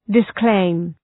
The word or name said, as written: disclaim